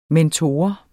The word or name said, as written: mentor